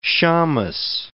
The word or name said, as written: shammes